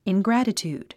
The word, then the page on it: ingratitude: ingrǽtət j ùːd; イングラタチュード